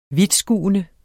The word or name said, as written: vidtskuende